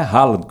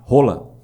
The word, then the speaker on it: Haaland: a pronúncia original não é Haaland. Haaland é nós que nós falamos a Haaland aqui. – Sim. – Mas a pronúncia é outra pronúncia. – Ah, é? – Rola.